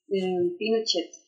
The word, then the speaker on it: Pinochet: Do you think this is truly the end of the Pinochet or Pinochet era, depending on how you pronounce it? – Pinochet.